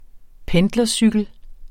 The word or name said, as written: pendlercykel